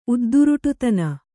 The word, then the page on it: ಉದ್ದುರುಟುತನ: udduruṭutana